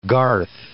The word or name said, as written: garth